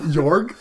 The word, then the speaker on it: Jorg: Yorg? – That's not... – Can you spell that for me? – J-O-R-G? – Yeah, it's how it sounds.